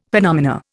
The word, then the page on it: phenomena: Indian